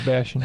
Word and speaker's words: Bashan: Many bulls have surrounded me, strong bulls of, how do you pronounce that, Bashan? – Mm-hmm. Yeah, Bashan.